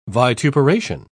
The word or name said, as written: vituperation